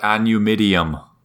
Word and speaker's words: Anumidium: What about Anumidium? – Oh, that's a pun on a new medium. – I see. Because it sounds like the way the Brits say aluminum.